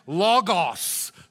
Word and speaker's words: logos: It is pronounced logos.